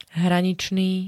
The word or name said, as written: hraničný